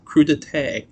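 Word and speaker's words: crudité: I looked up the pronunciation. I had someone pronounce it. An actual Frenchman, it is crudite.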